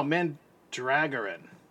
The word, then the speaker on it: Mandragoran: I'm saying his name wrong. Land something. No, Mandragoran.